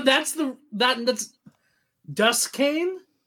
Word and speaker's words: Duquesne: Duquesne. – Yeah. Duquesne. – There we go. There we go. But it's pronounced Duquesne.